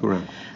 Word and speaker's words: Cuarón: Did I say his name right? I think I did. – Cuarón. – Okay, Cuarón. Sorry.